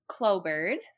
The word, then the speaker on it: Clobyrd: Yeah, my Instagram is Clobird.